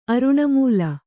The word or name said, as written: ಅರುಣಮೂಲ